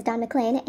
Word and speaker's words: Don McLean: Okay, well, as it turns out, the pronunciation is Don McLean, and I was just reading a factoid from Google trying to sound smart, so there you go.